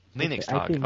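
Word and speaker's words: LinuxTag: Linux Tag, okay. – I can't remember it. – Here it's Linux. We use the Linux pronunciation, but Tag, of course, is the German word for day.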